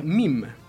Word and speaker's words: meme: Bien, pero sigamos hablando de la palabrita meme, que es un neologismo creado en inglés, pero que en dicha lengua se pronuncia meme.